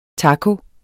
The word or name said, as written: taco